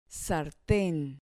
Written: sarten

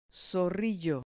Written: zorrillo

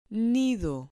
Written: nido

